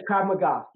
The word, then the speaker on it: Krav Maga: Was this second degree black belt in Krav Maga? Am I saying it right? – Yes, Krav Maga.